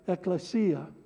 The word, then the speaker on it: ekklesia: And the best place to start is the very definition of the word church, which is the Greek word ekklesia.